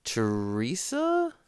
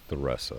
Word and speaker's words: Theresa: We met coordinator of the Rock River Trail Initiative, and president of the village of Teresa? – The native pronunciation is Theresa. – Theresa, okay.